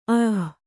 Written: ಅಅಃ

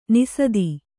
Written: ನಿಸಾದಿ

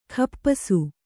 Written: ಖಪ್ಪಸು

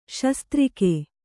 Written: ಶಾಸ್ತ್ರಿಗ

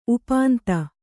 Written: ಉಪಾಂತ

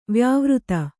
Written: ವ್ಯಾವೃತ್ತಿ

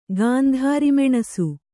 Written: ಗಾಂಧಾರಿಮೆಣಸು

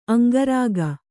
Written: ಅಂಗರಕ್ಕೆ

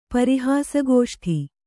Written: ಪರಿಹಾಸಗೋಷ್ಠಿ

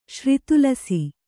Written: ಶ್ರಿತುಲಸಿ

